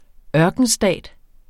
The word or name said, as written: ørkenstat